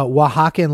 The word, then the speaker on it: Oaxaca: So that's that's pronounced Oaxaca, which Oaxacan. – Is a region. It's a state in Mexico.